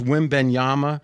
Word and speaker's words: Wembanyama: But the same injury concerns you have about him, you should have about Wembanyama, because they're built very similar. – But that's not just the build. Is my understanding about again? It's Wembanyama.